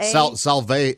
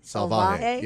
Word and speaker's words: Salvaje: It's called Cosa Salave. – Salave. – Oh, S-A-L-A-V-A. – You just made him dog saliva? – Yeah. – How would you pronounce that? – Spit on my house. – S-A-L-A. – Salvaje. Salvaje. Yeah, S-A-L-V-A-J-E.